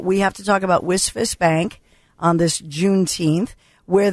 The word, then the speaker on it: WSFS: WisFist, WSFS, we stand for service, pronounced WisFist.